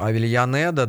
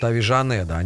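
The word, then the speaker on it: Avellaneda: Или в Аргентине, если по правилам классического испанского, к примеру, «Авильянеда», да, то аргентинцы говорят «Авижанеда». Они все время жекают.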